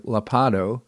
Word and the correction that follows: Ladapo: I think it's Ladapo, that's what it is,